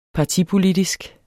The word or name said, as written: partipolitisk